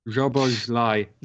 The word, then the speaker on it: Szoboszlai: Maybe it's Dominic Szoboszlai. Anyone, how do you say that name? – Szoboszlai.